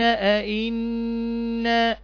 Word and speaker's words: أئنا: خاشعة يقولون، يقولون أئنا، أئنا، عندنا هنا ايه؟ النون مشددة تاخد ايه؟ غنة حركتين، فتنطق هكذا أئنا أئنا. بيقول الهمزة، ما لها الهمزة؟ أئنا الهمزة همزة قطع، بيبقى همزتين أئنا، لأن هنا للاستفهام أئنا، هم بيستنكروا أئنا لمردودون في الحافرة، كأن الكافرين يعني يشككون في أنهم سيعودون بعد ذلك بعد الموت.